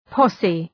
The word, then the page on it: posse: ‘pɒsı